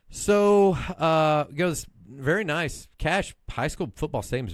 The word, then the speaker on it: Cache: So went to Cache, Oklahoma. – Spell that for me. – Cache. C-A-C-H-E. – I would have spelled it C-A-S-H.